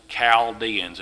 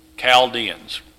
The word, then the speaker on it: Chaldeans: It's all pronounced Chaldeans. It's not Chal. The H is silent when you pronounce the word.